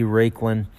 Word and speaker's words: Raiklin: Raiklin. That's spelled R-A-I-K-L-I-N.